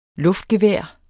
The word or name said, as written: luftgevær